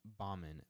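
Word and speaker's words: Gbamin: Jean-Philippe Baumann, but it's actually G. Baumann. – So. Silent G? – Yeah, Silent G.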